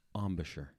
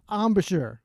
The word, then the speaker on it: embouchure: Embouchure. Bryan's embouchure is becoming more stable thanks to Jason Solomon. Embouchure.